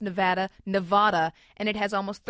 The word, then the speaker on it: Nevada: Outgoing Assemblyman Harry Mortensen is now working on a resolution that would make Nevada an equally acceptable pronunciation as Nevada.